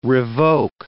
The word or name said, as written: revoke